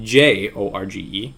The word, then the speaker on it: Jorge: According to Deputy Mayor George Blass, George spelled with J O R G E. – It's Jorge.